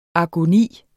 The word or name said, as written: agoni